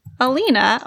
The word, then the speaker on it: Alina: A-L-I-N-A is how it's spelled. – Alina or Alina. Well, it's because the I could be pronounced different ways.